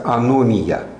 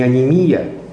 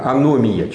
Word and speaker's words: аномия: Эта ситуация описывается одним очень интересным термином, который называется аномия. Не анемия, я уже с журналистами разговариваю, а потом везде читаю. Анемия, анемия. Друзья мои, аномия через О и ударение там на втором слоге. Аномия.